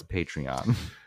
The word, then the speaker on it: Patreon: We're going to start a Patreon or patron. I'm not quite sure how to pronounce it. Uh, I didn't bother to look it up. – I but it's Patreon. But but I've I've already started it.